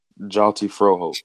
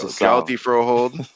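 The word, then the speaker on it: Hjalte Froholdt: How do we say his name again? This gentleman from Denmark? – Jalti Froholt. – There we go. Jalti Froholt.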